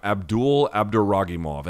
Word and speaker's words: Abdoul Abdouraguimov: Abdul Abduragimov. And it's spelled a little bit strangely, but it's Ares, A-R-E-S-F-C-7.